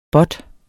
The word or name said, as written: båt